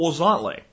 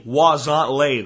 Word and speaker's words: Huauzontle: It's called Wozontle. And you spell it H-U-A-U-Z-O-N-T-L-E. Again, H-U-A-U-Z-O-N-T-L-E. Wozontle, though, it's pronounced.